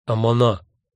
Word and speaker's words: Амана: Выдержав долгую утомительную паузу, старик наконец вымолвил одно единственное слово, что-то вроде «Амана» с ударением на последнем слоге.